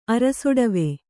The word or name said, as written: ಅರಸುಠೀವಿ